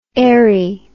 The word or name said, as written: aeri